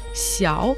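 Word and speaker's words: 小: Слово «лиан» читается третьим тоном, означает «два оба», «сяо» в третьем тоне, имеется в виду «маленький», «у» произносится вторым тоном, означает «не иметь», «цай» в первом тоне, означает «подозрение», «недоверие», «сомнение».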